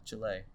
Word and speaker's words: Chile: They're gonna burn some building down, you know, just like in Chile. Yeah, Chile. – Chile? – Yeah, that's how you say it. Really? Not Chile. That's white. It'll be white.